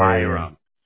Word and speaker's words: Hiram: H-I-R-A-M. – Hiram.